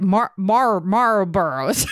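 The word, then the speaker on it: Marlboro: Is that how you say it? Marlboros. – Yeah.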